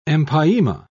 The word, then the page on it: empyema: British English